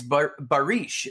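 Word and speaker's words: Barış: It's Barish is how you say it.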